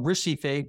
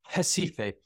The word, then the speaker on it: Recife: Up here is pretty much urban northeast Brazil, and it's centered around, it's spelled Recife, but it's Recife.